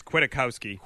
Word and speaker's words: Kwiatkowski: And for the Raiders, Henry Ruggs, which we knew about, Amik Robertson, Madre Harper, Nick Quig, I always get this name wrong. – It's Kwiatkowski.